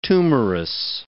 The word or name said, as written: tumorous